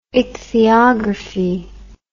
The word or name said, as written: ichthyography